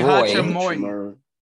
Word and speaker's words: Hachimura: You had Austin Reeves and Roy Hachimura. I think that's how you say his name. – Roy Hachimura. – Thank you, guys.